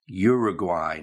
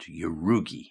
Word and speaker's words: Uruguay: but the pronunciation is Uruguay, not Urugi.